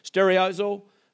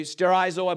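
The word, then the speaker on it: sterizo (στηρίζω): The Greek word here is stereizo. Okay, sterizo, I believe is actually how it's pronounced.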